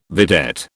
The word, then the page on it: vedette: British English